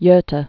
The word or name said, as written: Gota Canal